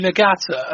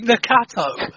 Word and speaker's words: Nagata: Sorry, Craig, you asked us before we went on air about how to pronounce Yuji Nagata, and then you said Yuji Nakato.